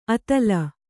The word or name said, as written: ಅತಲ